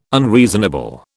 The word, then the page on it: unreasonable: British English